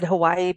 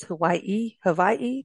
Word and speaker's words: Hawaii: It's, we've always said Hawaii, but it's Hawaii, Yes.